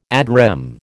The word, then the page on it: ad rem: American English